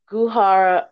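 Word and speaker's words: Goo Hara: And for the other girl, I don't want to mispronounce her name, but I think it's Guhara, right? That's right? – Yeah. – Okay, Guhara. I knew she was from the group, I think it's K-A-R-A.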